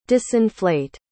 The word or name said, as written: disinflate